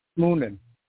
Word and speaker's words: Moonen: Moonen. It's like Noonan.